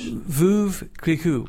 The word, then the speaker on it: Veuve Clicquot: Veuve Clicquot.